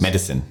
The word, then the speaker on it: medicine: Oder Medicine, spricht man es aus? – Medicine. Your love is like bad medicine.